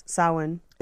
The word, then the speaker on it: Samhain: Samhain? – Yes, Samhain.